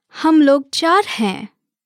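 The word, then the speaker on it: Hum log char hain (हम लोग चार हैं): Let's break it down by syllable. Hum log char hain. Now let's hear it once again. Hum log char hain.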